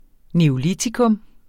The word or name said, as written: NEOLITIKUM